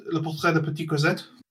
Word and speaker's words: Le Portrait de Petite Cosette: Okay, our next normal episode, Wink uh, will be on Le Portrait de Petite Cosette. Is that okay for French pronunciation? Yeah, Le, Port- Le Portrait de Petite Cosette. Much okay. Better.